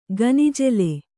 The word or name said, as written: ಗೊನಜಾಲಿ